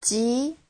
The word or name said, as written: ji2